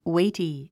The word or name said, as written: weighty